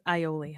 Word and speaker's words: aioli: It's aioli, honey. – Aioli. – Aioli. – But there's an A. – I know. It's confused. – I hate silent letters.